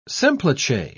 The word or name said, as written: semplice